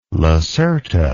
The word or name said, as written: lacerta